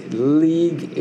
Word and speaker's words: lieg: Notice, lieg is L-I-G, it's a long E.